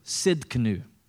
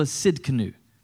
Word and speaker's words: Tsidkenu: And this Hebrew word is Jehovah Tsidkenu. And the T here is silent. So I remember it by saying Jehovah sit in a canoe. You'll remember it that way. Jehovah Tsidkenu.